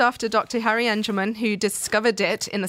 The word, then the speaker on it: Angelman: Every time I say it, I worry I'm saying it wrong, but it's Angelman. – It is Angelman.